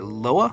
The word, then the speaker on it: loa: Paba Legba is a loa, am I saying that right? – Yeah, sure.